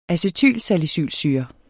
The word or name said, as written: acetylsalicylsyre